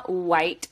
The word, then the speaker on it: Wight: You can find me on Instagram and TikTok at Sabrina Wight 43. My last name is spelled W-I-G-H-T. If you throw an R in it, if it's right, it's wrong.